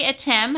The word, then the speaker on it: Atem: So again, we're talking with Father Henry Atem.